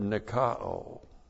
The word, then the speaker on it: nikao: An articular participle from the verb nikao. N-I-K-A long O.